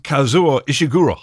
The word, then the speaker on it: Kazuo Ishiguro: Excuse me, what did you just say and how did you just pronounce the Nobel Peace Prize winner's name? – Yeah. – But you. – I nailed it. – Wow. – Kazuo Ishiguro.